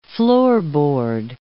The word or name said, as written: floorboard